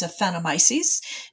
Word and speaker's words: aphanomyces: So we're talking about a disease that a lot of people would have a hard time pronouncing, but the way I pronounce it is aphanomyces. Is that how you pronounce it? – That is exactly how I pronounce it.